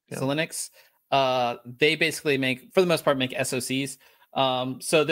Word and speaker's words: Xilinx: Xilinx.